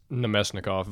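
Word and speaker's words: Namestnikov (Наместников): It's Namestnikov, Vladislav Namestnikov.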